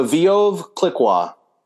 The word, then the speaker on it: Veuve Clicquot: which is the Veuve Clicquot.